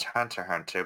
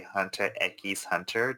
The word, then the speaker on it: Hunter x Hunter: Funny thing, I found out about 2 years ago it's actually pronounced Hunter Hunter, but in Spanish I will happily say Hunter x Hunter just because I like saying x's.